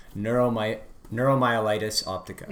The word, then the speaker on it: neuromyelitis optica: Right, and the name of it, let's see if I can pronounce it, neuromyelitis optica. – Yeah. – Is that right? – Yeah, that's it.